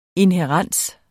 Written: inhærens

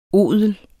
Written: odel

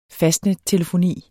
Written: fastnettelefoni

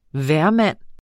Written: hvermand

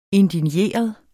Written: indigneret

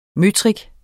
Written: møtrik

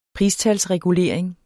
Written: pristalsregulering

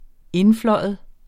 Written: INDFLYVER

